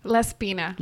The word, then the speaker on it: Laspina: I hope I'm saying that right. – Laspina. – Laspina. – Very close. – Laspina. I have to work on my Spanish.